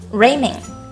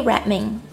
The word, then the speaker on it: Redmayne: Redmayne 容易被读作成 Raymayne 正确名字的读音应该是 Eddie Redmayne